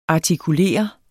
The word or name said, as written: artikulere